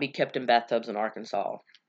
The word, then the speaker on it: Arkansas: um, you must pronounce Arkansas as Arkansas. Apparently, Arkansans are offended, maybe, by it being called anything else other than the correct pronunciation.